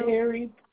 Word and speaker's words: Perry: Perry, P-E-R-R-Y. – P-E-R-R-Y.